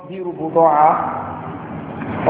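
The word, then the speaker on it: بضاعة: So this is called بِيرُ بُضَاعَ or بِيرُ بِضَاعَ As some scholars will call it, both pronunciations are correct.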